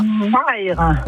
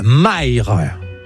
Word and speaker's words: Mayres: Vous habitez à... – Maire. – Et ça s'écrit ? – M-A-Y-R-E-S. – Voilà, Maire.